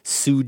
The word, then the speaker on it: Tsu: And if connections to Zombieland still aren't clear, here's Gordon Danford writing in from Sioux, Japan. Do you think I'm saying that right? TSU? – That was perfect.